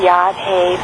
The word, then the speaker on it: Yahweh: There's the name of the god, Yahweh, pronounced Yahweh, that expresses the meaning of the whole tree of life.